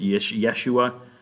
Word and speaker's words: Yeshua: All right, he's God, and so yep, they his name is Jesus, or they would have pronounced it Yeshua in his day.